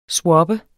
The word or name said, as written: swappe